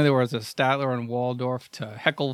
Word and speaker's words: Waldorf: But I was like Statler and Wardorf are just... – Waldorf. – That's, I always say it wrong.